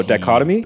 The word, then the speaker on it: dichotomy: Dictamy? – What? – The one that starts with the D I C H O. – Oh, dichotomy? – Oh, thank you.